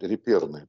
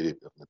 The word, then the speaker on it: реперные: А правильно говорить реперные точки, не реперные, потому что репер – это французское слово, ударение на последнее слово.